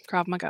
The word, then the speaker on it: Krav Maga: Where do you think I'm going to get you a Krav Maga teacher?" – Is that how you say that? Because that's how I think it's said, but I'm not sure. – Yes, that is how it's said. Krav Maga.